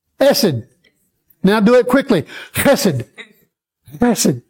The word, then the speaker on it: chesed: It's not k, which would be the thought if somebody saw it printed in some references, printed C-H-E-S-E-D. But that could also be chesed. Some translations or reference manuals spell it K-H-E-S-E-D, which would be more like the chesed. But it's not chesed, and it's not chesed. It is chesed.